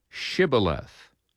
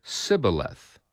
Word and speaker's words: Shibboleth: If he said no, then they would say to him, Then say Shibboleth. And he would say Sibboleth, for he could not pronounce it right.